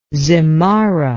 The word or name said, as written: zimarra